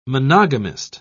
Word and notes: monogamist: British English

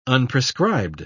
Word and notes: unprescribed: British English

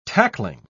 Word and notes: tackling: British English

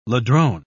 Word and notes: ladrone: British English

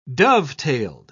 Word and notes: dovetailed: British English

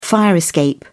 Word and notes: fire escape: British English

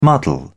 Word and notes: muddle: British English

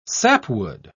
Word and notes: sapwood: British English

